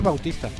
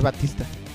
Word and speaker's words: Bautista: Ah, es que se escribe Bautista. – Pero se lee Batista. – Bueno, porque lo pronuncian yo creo mal los gringos, ¿no? – Sí, es lo más seguro.